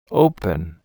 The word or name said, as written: open